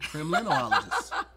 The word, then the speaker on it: Kremlinologist: Yep, both of those sound stupid, so I'm gonna go with Kremlinologist. Kremlinologist. – Correct. – Yeah. – All right. Kremlinologist is somebody that has mastered crime and they surpass the level of just regular criminal. They are a Kremlinologist.